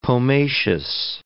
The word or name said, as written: pomaceous